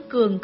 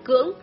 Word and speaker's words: cưỡng: Chữ cường có thể đọc là cưỡng, tức ước hiếp thiên hạ, dưới cũng vậy.